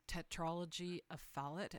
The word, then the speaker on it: tetralogy of Fallot: Or I have heard of like tetralogy of Fallot, and I don't even know if I've said that correctly. – You did. That's pretty good.